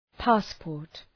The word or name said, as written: passport